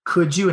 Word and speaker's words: could you: And it has that J, J sound, even though it's a D. – Sure. – Could and would are spelled with a D, but we throw that J sound with the pronoun you.